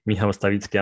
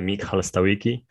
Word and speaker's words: Michał Stawicki: Like type my name, Michał Stawicki Amazon, or like you Americans say, uh, Michal Stawicki, Amazon or Medium on,